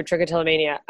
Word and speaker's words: trichotillomania: Because I just blasted through trichotillomania.